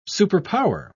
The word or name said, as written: superpower